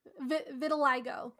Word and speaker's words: vitiligo: Vitiligo? – Vitiligo.